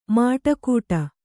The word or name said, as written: ಮಡಿಕಟ್ಟು